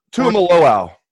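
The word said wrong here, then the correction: Tuimoloau: Close. Tumi Moloau. – Tumi Moloau. Okay. – Tui? Yeah, JT Tui Moloau is how he pronounces that.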